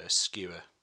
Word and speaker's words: Skua: And then I also kind of put a bit out under my own alias, which is Skewer. Which is spelled SKUA, like the bird,